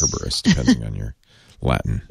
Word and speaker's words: Cerberus: it complements the work Microsoft's been doing with the Open Compute Project around Project Cerberus. Is that how you say that? C-E-R-B-E-R-U-S? – Kerberos. Kerberos. – Kerberos. – Oh, Kerberos. I think I said this wrong the last time.